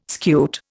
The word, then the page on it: scute: Indian